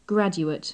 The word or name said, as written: graduate